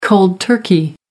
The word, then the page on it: cold turkey: American English